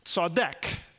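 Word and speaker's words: tzedek (צדק): Tzedek is a Hebrew word, and it's pronounced tzedek. Like I was thinking of, it sounds like an English phrase, you know, if you saw my deck, right? Take out the word my. Saw my deck, except the front end is a TZ, like at the end of our word, like shirts or alerts.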